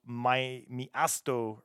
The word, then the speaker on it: Miasto: They released an album called Miasto, which is M-I-A-S-T-O. And is there a help? There is. It's a Polish word. It translates to city. It's pronounced Miasto.